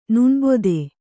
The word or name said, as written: ನಾಣ್ಬದ